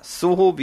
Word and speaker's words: Zoho: Ja, Zoho oder Soho, wie es wohl englisch ausgesprochen wird,